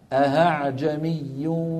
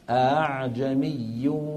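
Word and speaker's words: أأعجمي: أو أن يقلبها هاء بأن يوسع بين الوترين فبصير النطق هاء أعجمي، هذا ما بصح، هذا ما بصح، لأنه نحن خرجنا من عالم الهمزة إلى عالم ثاني هو حرف الهاء.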